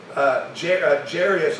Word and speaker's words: Jairus: The Bible says in verse 41, "And behold, there came a man named Jairus," or Jairus, depending on how you want to say that. Jairus is how I pronounce it.